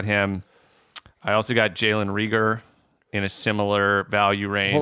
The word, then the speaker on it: Reagor: Apparently, his name is pronounced Jalen Reagor. – Oh. – Like Ray Gunn, but Reagor.